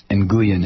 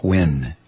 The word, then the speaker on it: Nguyen: And as Andrea writes in, it is not pronounced Nguyen, it's pronounced Win. So his name should have been pronounced Na Win.